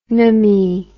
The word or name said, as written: nomy